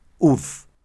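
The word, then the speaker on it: œuf: The word for egg in French is pronounced ouf.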